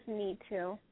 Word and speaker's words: Nitu: My name is Neetu. – Spell that for me. – It's spelled N-I-T-U. – N-I-T-U.